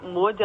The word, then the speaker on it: Maud: Então, eu tenho o meu Instagram, que é @mariamod, Mod se pronuncia, né, mas se escreve MAUD, então assim, Maud, né? Então é Maria Maud com dois D's no final. Assim, meu nome é só com um D, mas no Instagram tem dois D's, então Maria Maud, MAUD.